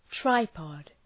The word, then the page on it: tripod: ‘traıpɒd